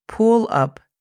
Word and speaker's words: pull up: Pull up significa levántate o detente. La pronunciación fonética es P-U-L, pull, A-P, up, pull up.